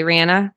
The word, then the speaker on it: Reanna: Thanks for coming on to join us today, Reanna. How you doing? Good, good. Thanks for having me. Um, first off, um, your name is spelled and sounds like pop star Rihanna.